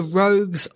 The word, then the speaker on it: Rogz: So it's called the Rogues Lighthouse, which is spelt R-O-G-Z or Z, depending on where you live.